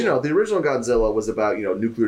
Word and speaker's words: Godzilla: He doesn't pronounce it right. He doesn't do like Godzilla. He doesn't put the emphasis on the God.